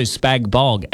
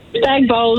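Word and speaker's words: spag bol: Spag bowl, the G is silent.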